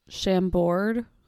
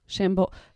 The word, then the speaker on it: Chambord: Okay, it appears to be vodka, Chambord. I think I'm saying that wrong. Chambord. I think that's the French pronunciation. Chambord. I feel like I'm just insulting the French now.